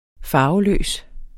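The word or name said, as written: FARVELØS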